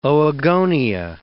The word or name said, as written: oogonia